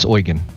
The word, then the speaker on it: Eugen: This ship has been in the game for a long time, and that is the Prince Eugen, and I think I'm pronouncing that correctly. The Eugen? – Correct. Eugen. – Yeah, yep. Prince Eugen.